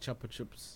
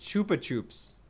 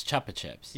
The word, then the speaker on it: Chupa Chups: They look exactly like Chupa Chups. – Why do you pronounce it like that? – Chupa Chips? – It's just Chupa Chups. Chupa Chupas. – No. It's Chupa Chips.